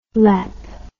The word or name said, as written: lep